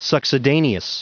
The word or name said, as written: succedaneous